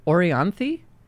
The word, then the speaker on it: Orianthi: And you went with According to You by Orianthi.